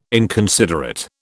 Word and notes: inconsiderate: British English

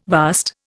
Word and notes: vast: Indian

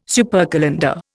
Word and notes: supercalender: Indian

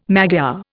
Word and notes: Magyar: Indian

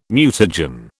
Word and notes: mutagen: British English

